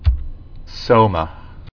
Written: Soma

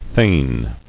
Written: Thaneship

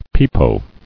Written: Pepo

